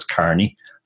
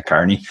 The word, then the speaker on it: Carney: Actually, the second name people generally get wrong as well because of the EA, which looks like E, but it's actually pronounced just like an A. So it's Carney, as in somebody who works in a Carney. – So I think I said that wrong too. So Daro Carney. – That's it. Yeah, Daro Carney. Perfect.